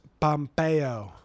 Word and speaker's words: Pompeo: Mike Pompeo? Is that how you pronounce his name? – His name's Pompeo. – Pompeo. So, Mike Pompeo... Like the city that got buried in lava.